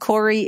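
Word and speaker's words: Cori: And Corey is, it's very Canadian. It's spelt like sorry, just take off the S and stick on a C.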